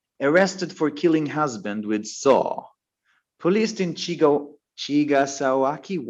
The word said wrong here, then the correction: Chigasaki: What? How do I say it? Chigasaki.